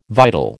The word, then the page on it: vital: American English